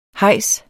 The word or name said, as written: hejs